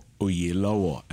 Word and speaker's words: Oyelowo: Well, I mean, the name itself, Oyelowo, or Oyelowo, as it's pronounced in the Yoruba tradition, means a king deserves respect.